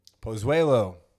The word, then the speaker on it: Pozuelo: Pozuelo.